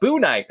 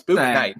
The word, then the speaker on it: Bouknight: the fact that you have the word night where I shouldn't pronounce the K, but then you do pronounce it because it's at the end of Book, just confuses me every time. I'm like, Boo Knight. I'm like, no, it's Book Knight.